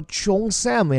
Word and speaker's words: cheongsam: 但是这件能把美国亚裔惹到如此火大的这件衣服叫做 Cheongsam，这件中式衣服，大多数中国人听来并不熟悉。其实它指的就是我们的旗袍啊，但旗袍这个发音怎么也不太可能发成叫 Cheongsam 呀。其实啊，这个词来自于粤语，就是早年间去到海外的主要是咱广东人，就这种中式的比较长条的衣服，也不只是旗袍，一概被称作 Cheongsam，对应的中文原文其实叫做长衫。